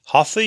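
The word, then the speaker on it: hatha (हठ): Hatha yoga, again, are the Hindu devotional postures, and the very meaning of the Sanskrit Hindu word hatha, it's two syllables, ha and tha.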